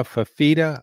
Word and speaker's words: Fifita: It struggled early in the year, but Noah Fifita, I may be mispronouncing that name. – I think you nailed it.